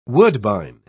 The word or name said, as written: woodbine